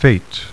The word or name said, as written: fate